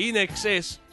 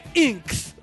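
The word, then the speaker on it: INXS: Yo tengo un vinilo de In Excess, que era de mi tío, y tenía un sticker que te pone, se pronuncia... In excess. – Ah. – In excess. – In excess. – Porque la gente decía inks.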